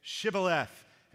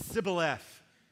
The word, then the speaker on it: Shibboleth: When he said, No, they said to him, Say Shibboleth. And he said, Sibboleth, because he couldn't pronounce it right.